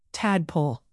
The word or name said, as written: tadpole